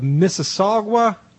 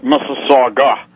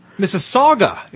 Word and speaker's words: Mississauga: On the line with us now, our featured guest, Paul Fromm, running for mayor of Mississauga, Canada. – It's pronounced Mississauga. – Mississauga, excuse me.